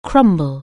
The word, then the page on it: crumble: anglais